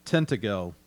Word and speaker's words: Tintagel: I do know that, so we're talking about, for a second, this Tintagel. I believe that's how you say it. It's T-I-N-T-A-G-E-L, Tintagel.